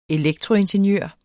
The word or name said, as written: elektroingeniør